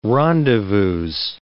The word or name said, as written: rendezvouses